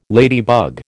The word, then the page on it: ladybug: American English